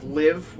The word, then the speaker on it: LIV: We've been seeing some news for a few weeks now about the new golf league, LIV, which is, it rhymes with give.